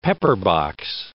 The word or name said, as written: pepperbox